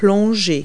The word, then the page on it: dive: verb